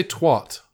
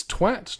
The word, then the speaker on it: twat: You say twat. – Yeah. Because I think that's how we interpret British accents. – No. It's twat to rhyme with cat and hat and bat and sat shat.